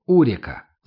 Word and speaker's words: Урика: Я знал, что Аня живет в нижней половине города, ближе к реке, которая так и называлась в народе Урика, с ударением на первом слоге.